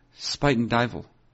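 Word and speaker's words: Spuyten Duyvil: S-P-U-Y-T-E-N and then D-U-Y-V-I-L. Spuyten Duyvel. I believe it's pronounced.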